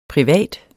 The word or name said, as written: privat